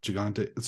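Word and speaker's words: Gigandet: I think, well, you know, the you pronounce the D at the end like Gigante, so it sounds like a T, you know.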